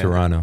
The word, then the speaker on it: Toronto: Toronto. – Toronto. – There's no the T is silent.